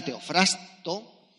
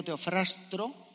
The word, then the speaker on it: Teofrasto: Teofrasto, porque a veces digo Teofrastro y entonces me siento mal. – Te habría corregido. Teofrasto. Seguro. Entonces, eh, Teofrasto es un, es un filósofo y lapidario, ¿vale? – Filósofo y lapidario.